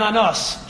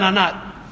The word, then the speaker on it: Anat: The third shofet is Shamgar, Shamgar ben Anas, Shamgar ben Anat. Anas is spelled Ayin Nun Tuf.